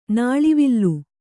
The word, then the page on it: ನುಲುವಾಲೆ: nuluvāle